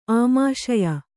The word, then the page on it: ಆಮಾಶಯ: āmāśaya